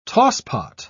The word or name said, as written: tosspot